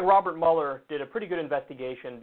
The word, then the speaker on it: Mueller: So it looks like Robert Mueller, and by the way, people tell me it's not pronounced Mueller. I was pronouncing it Mueller for a while because that's how it's fucking spelled, but people are saying, no, it's Mueller, so whatever.